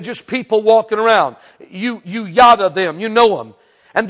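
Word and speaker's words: yada (ידע): The word here for know, K-N-O-W, the word for know your God, is the Hebrew word in the Old Testament called yada. I believe you spell it Y-A-D-A.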